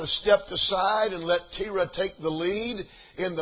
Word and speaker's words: Terah: I looked up that name, and it's actually pronounced, I pronounced it Terah, but the way they pronounced it was Terah.